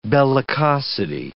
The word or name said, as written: bellicosity